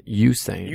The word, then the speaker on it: Usain: Corey pulled an, how do you say his name? Usain Bolt? – Usain. – Usain Bolt? – Yeah.